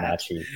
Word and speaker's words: Wenatchee: Thank you, by the way, for correcting me on the pronunciation of that. – Wenatchee, yes. Wenatchee.